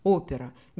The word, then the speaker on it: опера: А вот слово «опера» произносится совсем по-русски, то есть «опера».